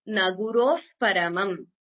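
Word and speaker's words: na guroḥ paramam: When you chant it together, it becomes naguroh paramam.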